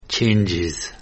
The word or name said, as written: Changes